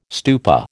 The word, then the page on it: stupa: American English